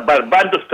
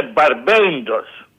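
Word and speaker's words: Barbados: Να, και τα άλλα, τα νησιά, τα Μπαρμπάντος, τα λένε, τη σωστή προφορά είναι Μπαρμπέντος.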